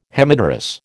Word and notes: Hemipterous: American English

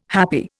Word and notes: happi: Indian

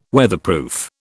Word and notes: Weatherproof: British English

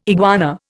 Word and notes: iguana: Indian

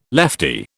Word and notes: leftie: British English

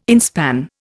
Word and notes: inspan: Indian

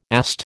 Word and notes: AST: American English

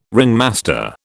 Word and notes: ringmaster: British English

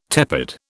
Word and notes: tepid: British English

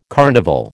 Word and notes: carnival: American English

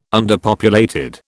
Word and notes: underpopulated: British English

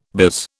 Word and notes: biz: British English

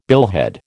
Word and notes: billhead: American English

